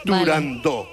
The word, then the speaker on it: Turandot: Para que quede claro, es Turandot.